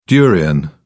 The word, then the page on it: durian: British English